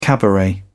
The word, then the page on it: cabaret: British English